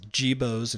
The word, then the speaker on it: Gebo's: But if you have a tractor supply, or there's another Jibo's. Jibo's which is spelled G-E-B-O apostrophe S. Gebo's and Tractor Supply both have versions of Chick Days.